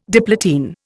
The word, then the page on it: diplotene: Indian